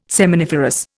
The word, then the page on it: seminiferous: Indian